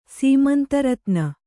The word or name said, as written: ಸೀಮಂತರತ್ನ